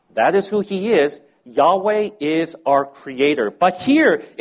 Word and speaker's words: Yahweh: And through other ways, we know that the second part of the name was pronounced Weh. Yahweh. That was most likely the way that the name of God was originally pronounced.